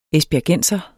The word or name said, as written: esbjergenser